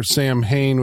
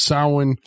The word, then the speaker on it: Samhain: And we're told, of course, if you know this, that it's short for Sam Hain, which is apparently pronounced Samhain. – Samhain, yes. That is the correct pronunciation.